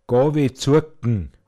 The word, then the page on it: buglkraggs tråogn: Pinzgauer Mundart